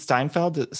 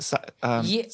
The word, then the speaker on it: Stanfield: Lakeith Steinfeld? Stanfield.